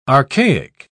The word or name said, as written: archaic